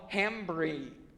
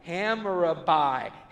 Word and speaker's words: Hammurabi: There was a tablet they found called the Law of Hammurabi. I totally just butchered that. Hammurabi.